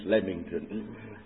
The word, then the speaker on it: Leamington: Also, I shall have to remind our presiding brother that the English language is notoriously perverse over pronunciation, and you call it Leamington. I know it looks like lean, but it isn't. It's Leamington.